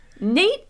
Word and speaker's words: Nayte: Yeah, it's spelled N-A-Y-T-E, so if you're going to look him up. First of all, Nate is 6'8".